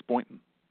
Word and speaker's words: Boynton: Oh, you said it right. It's Boynton.